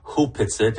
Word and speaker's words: Opetsit: It's written Opetsit, but it's Opetsit. Actually pronounced properly Hupetsit.